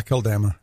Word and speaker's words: Akeldama: And it became known to all inhabitants of Jerusalem, so that the field was called in their own language Akeldama? How do you say that? – Yeah, Akeldama.